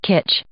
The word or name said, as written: kitsch